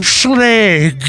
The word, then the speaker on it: Schlage: It is Schlage. – Schlage. What is it? What is it? – It's Schlage with a Schlage. Hard G.